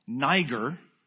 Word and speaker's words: Niger: Technically, this word is pronounced Niger, which is way too close to a word that does not belong ever on the words of a Jesus follower.